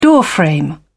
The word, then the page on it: doorframe: British English